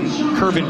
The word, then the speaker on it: Kervin: Bring way to Kevin Kervin Pichardo. Kervin, whose name is spelled like Kevin, but with an R in the middle.